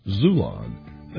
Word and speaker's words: Xulon: Get your paperback copy on Amazon, Barnes & Nobles, or from my publisher, Zulon. That's spelled X-U-L-O-N. For our Baltimore Metro friends, you stay tuned for our official book release and book signing.